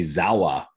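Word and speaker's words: sauer: And if they spoke German, it would be Zawa. Is how they Zawa. Pronounce it.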